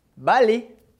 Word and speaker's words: ballet: Ballet. som do L com som de L, balé. Lembrando que o T do final de palavra, como regra geral, a gente não pronuncia, balé.